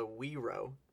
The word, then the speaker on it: guiro: That's called a Wiro, spelled like G-U-I-R-O.